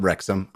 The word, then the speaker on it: Wrexham: One of them is it's called Wrexham. I get it. Welcome to Wrexham. I apologize for mispronouncing it.